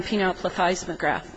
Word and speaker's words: penile plethysmograph: With respect to your question regarding penile plethysmograph, I did have to practice saying that.